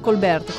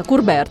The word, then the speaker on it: Courbet: Forse devi dire anche te Colbert, come si pronuncia? Courbet.